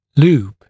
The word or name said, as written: loop